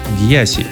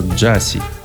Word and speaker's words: Gyasi: Итак, Гьяси, или как написано у него на сайте, правильно произносится Джаси.